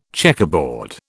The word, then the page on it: checkerboard: British English